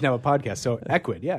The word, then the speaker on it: Ecwid: And that's kind of how I came across Equid.